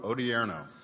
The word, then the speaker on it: Odierno: Odierno. – Odierno.